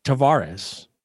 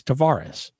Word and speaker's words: Tavares: So the question was put to him, how would your grandfather pronounce it? And he said, Tavares. And he says, that's the Portuguese way. It's Tavares.